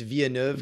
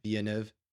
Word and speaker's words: Villeneuve: I think it's Villeneuve because it's a French-Canadian. Yeah, Villeneuve.